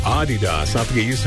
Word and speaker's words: Adidas: It's actually supposed to be Adidas. – Really? – Yeah, slight emphasis on the first syllable. Not Adidas. – Adidas. – Adidas. – All right. – Yeah. That's German. It's a German company, so.